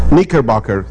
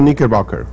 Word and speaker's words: Knickerbocker: ¿Cómo se dice? Knickerbocker. – Según Knickerbocker.